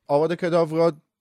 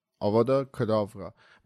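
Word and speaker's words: Avada Kedavra: آواد کداورا دقیقا تلفظ درست این هستش، یعنی آواد کداورا.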